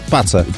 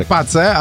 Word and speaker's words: PACE: Zachęca użytkowników smartfonów do fotografowania nieba w celu wsparcia badań prowadzonych przez satelitę Pace. – Pisze się Pace, ale chyba czyta się Pace. Skoro Space to Space, to Pace to musi być Pace.